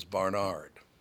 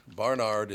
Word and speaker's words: Barnard: but my last name is Barnard, okay? Barnard is the name. He pronounces it Barnard.